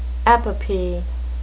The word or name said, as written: epopee